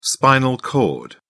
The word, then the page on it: spinal cord: British English